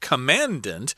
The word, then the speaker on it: commandant: Uh, that word looks like commandant, but that's not how you pronounce it.